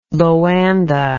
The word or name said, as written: Loanda